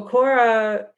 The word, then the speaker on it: Korah: Okay, because like it looks like it's spelled like Torah. So is it K-O-R-A-H? – I think so. – Then it's Korah. – Okay. So Korah,